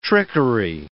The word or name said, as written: trickery